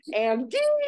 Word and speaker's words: Andie: It's pronounced Andy.